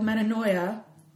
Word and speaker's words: Metanoia: Her latest is titled Metanoia. Am I saying that right? – You are.